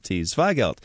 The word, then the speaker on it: Zweigelt: At least we can pronounce it. It's Zweigelt, Zweigelt, which is a cross of Blaufränkisch and Saint Laurent from Austria, and this is a really tasty red wine.